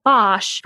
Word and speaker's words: Bosch: but he adopted the name Bosch from the place where he lived, which was commonly referred to as Den Bosch, and it's actually pronounced Bos in Dutch.